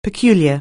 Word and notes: peculiar: anglais

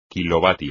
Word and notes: kilovatio: Spanish

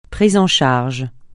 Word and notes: prise en charge: francais

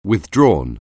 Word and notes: withdrawn: anglais

